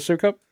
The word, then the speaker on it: Sukup: And Sukup. That's how it's pronounced. – Everything from concrete up is Sukup, right? – Yeah.